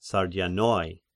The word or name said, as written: Sardians